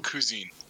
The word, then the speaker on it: Cousine: That's how you pronounce that? – Cousine. – Okay, Cousine. Called Cousins.